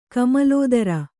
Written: ಕಮಲೋದರ

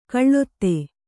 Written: ಖಳತಿ

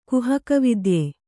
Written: ಕುಹಕವಿದ್ಯೆ

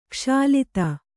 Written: ಕುಶಲತೆ

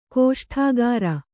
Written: ಗೋಷ್ಠಾಗಾರ

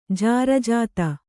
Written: ಜಾರಜಾತ

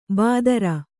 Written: ಬಾದರ

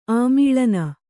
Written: ಆಮೇಲಿನ

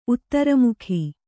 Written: ಉತ್ತರಮುಖಿ

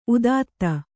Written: ಉತ್ತುತೆ

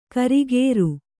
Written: ಕರಿಕಾರೆ

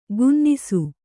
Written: ಗುನ್ನಿಸು

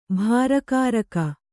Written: ಭಾರಕಾರಕ

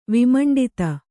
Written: ವಿಮಂಡಿತ

